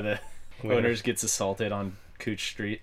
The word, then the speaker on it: Couch: So I guess to end this, any tourists coming to Portland, it's pronounced cooch here.